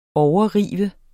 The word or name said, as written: overrive